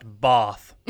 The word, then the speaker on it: Bath: It's pronounced Bath. It's England. It's England. It's pronounced. It's a Bath. – There's an invisible H in there, I guess.